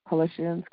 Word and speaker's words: Colossians: Colossians, am I pronouncing that correctly? Colossians. Okay.